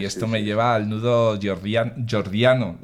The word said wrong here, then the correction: gordiano: ¿lo he dicho bien? Gordiano, sí, eso.